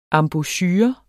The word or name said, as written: EMBOUCHUREN